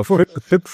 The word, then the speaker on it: FYB: Ist aber so wie spricht man das aus? FYB for Growth oder Formicon? Ja, genau. FIP for Growth.